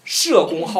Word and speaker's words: 叶公: 他后边讲是因为佛是讲，因为须菩提和谁？和和大家社，这个社是多音字，我们念成叶子的叶，是这样，我们讲社公好龙啊，现在都念成叶公好龙，因为它多音字，应该是社加大家社，社公好龙。